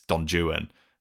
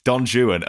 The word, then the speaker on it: Don Juan: And before anyone corrects me on that pronunciation, that is how the Byron version is apparently produced. Yeah, it's not. – I did think, oh my God, how embarrassing for him. – I could feel you like waiting to pounce and correct me. Don Juan, allegedly, is the Byron version's pronunciation.